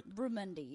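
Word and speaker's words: barramundi: Bramundi. Did I say that right? – Bramundi, yes.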